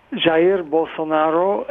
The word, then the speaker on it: Jair Bolsonaro: Jair Bolsonaro, and he's, well, you said most of what we need to know.